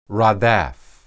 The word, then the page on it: radaph: hebrew